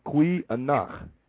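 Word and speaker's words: goeie nag: That's N-A-G. Kui anach. Good night. – Kui anach. – These spellings are G-O-E-I-E space N-A-G. Kui. G is spelled, is pronounced Q. So when you're pronouncing these, you have to pronounce it with the G with the Q. So it's kui nach.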